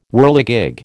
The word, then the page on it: whirligig: American English